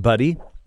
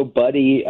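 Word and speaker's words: Buddhi: Talk to me about this other startup called, is it pronounced Buddy? – That's right. So Buddy, B-U-D-D-H-I, a really fun new startup in Chicago.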